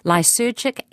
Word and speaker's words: lysergic: Tonight we're talking about, now please help me with the pronunciation, but I'm going to have a stab at it, lysergic acid diethylamide, LSD.